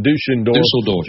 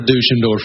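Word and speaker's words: Düsseldorf: You think that's pronounced Dusseldorf? – It is pronounced Dusseldorf. I was in Germany, 2 months ago. That's exactly how they would have said it.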